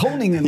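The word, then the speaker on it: Groningen: Also es ist jetzt nicht vergleichbar mit Du kannst es mal besser aussprechen mit Honingen. Mit Honingen. – ja, ganz genau.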